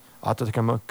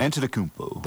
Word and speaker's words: Antetokounmpo: Giannis Antetokounmpo, I can't say his name. – Antetokounmpo.